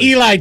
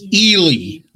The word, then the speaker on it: Ely: Not Eli. Tell him what it is, dog. – It's Ely, like Ron Ely.